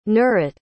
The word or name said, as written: nurit